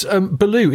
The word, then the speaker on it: Baloo: It's actually spelt differently. B-H-A-A-L-O-O. It would be the spelling in Hindi, but it's the same pronunciation. So, yeah, Baloo just means bear in Hindi.